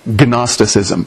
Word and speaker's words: Gnosticism: It starts with a G, a silent G, Gnosticism, from the Greek word gnosis, which is where we get our word knowledge.